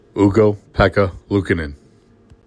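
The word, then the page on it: Ukko-Pekka Luukkonen: OO-koh-PEK-kah, LOO-koh-nehn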